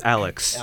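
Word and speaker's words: Alyx: It's just Alex. It's like a futuristic spelling of the name.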